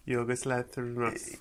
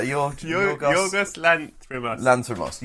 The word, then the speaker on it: Yorgos Lanthimos: Yorgos Lanthimos. – Yeah, Yorgos Lanthimos.